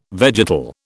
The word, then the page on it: vegetal: British English